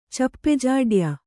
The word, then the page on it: ಚಪ್ಪೆಜಾಡ್ಯ: cappe jāḍya